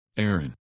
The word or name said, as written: Aaron